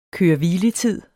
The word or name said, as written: køre-hvile-tid